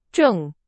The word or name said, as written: 证